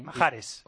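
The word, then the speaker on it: Mahrez: Sisto le llama Majarez. – Sí. – Majares. – O Majares. Y como sé que Sisto llama a las embajadas para saber cómo se dicen los nombres. – Sisto tiene cosas increíbles. – Y antes de Sisto. – Y entonces tengo un amigo que es argelino, le he llamado y le digo, mándame nota de voz cómo se dice. Y me ha dicho así.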